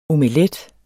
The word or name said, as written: OMELET